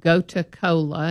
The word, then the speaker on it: Gotu Kola: You might look up the herb Gota Kola, and it's spelled G-O-T-U-K-O-L-A.